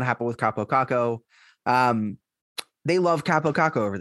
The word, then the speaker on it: Kaapo Kakko: Kaapo Kakko.